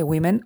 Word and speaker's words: women: pero ahora te encuentras la palabra women, el plural de woman, y resulta que women, la O se pronuncia I.